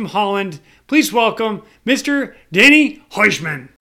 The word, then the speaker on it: Huisman: Huisman. Yes, that's Huisman.